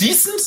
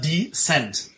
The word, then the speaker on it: Descent: Also nicht decent, sondern Descent.